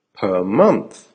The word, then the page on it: per month: American English